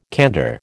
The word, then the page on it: candour: American English